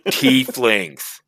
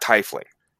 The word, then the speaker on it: Tiefling: Tieflings. – It's not Tiefling? – It's not Tiefling. It's Tiefling.